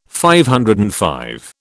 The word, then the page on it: DV: British English